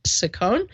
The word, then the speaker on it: Saccone: Some people call it Saccone, but I think he pronounced it American-wise Rick Saccone, and he's running against 33-year-old Connor Lamb.